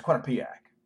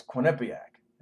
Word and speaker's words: Quinnipiac: And I, I, I, have read so many different, you know, pronunciations or whatever. It's Quinnipiac because it was Quinnipiac. You know. It's an Indian word. It's Quinnipiac.